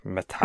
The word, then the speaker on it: Matthias Schoenaerts: Matthias.